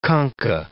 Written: concha

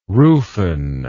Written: rufen

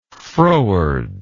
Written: frowardness

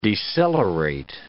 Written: decelerate

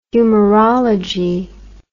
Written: humorology

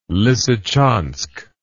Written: lisichansk

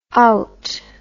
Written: owt

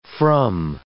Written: from

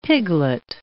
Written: piglet

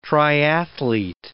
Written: triathlete

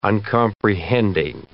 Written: uncomprehending

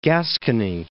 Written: Gascony

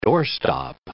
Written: doorstop

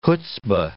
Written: chutzpa

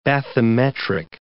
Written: bathymetric

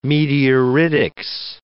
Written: meteoritics

